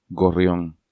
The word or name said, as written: Gorrion